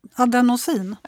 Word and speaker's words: adenosin: Och sen har du även adensoin. Uttalar jag det där rätt nu? Ad adenosin? Ja. Tur att jag har med dig.